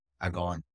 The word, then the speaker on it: Agwn: In my defense, as you probably see on the title, A-G-W-N. – Yes. – There's some letters missing there.